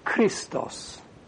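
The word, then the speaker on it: Christos (χρηστός): The word for good, by the way, is krestos in Greek, which even in the first century, the eta was already pronounced like an I. So they would have pronounced it Christos.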